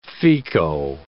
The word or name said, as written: fico